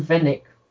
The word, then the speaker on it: Venatic: I say I'm going Vennec. – How do you spell it? – V-E-N-A-T-I-C. – Fanatic, yeah? – Yeah.